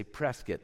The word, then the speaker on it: Prescott: They say Prescott like biscuit, they told me. Because I was saying Prescott, and the lady said, no, it's not, because we visited Todd's aunt and uncle. And she said, you're saying it wrong. It's Prescott.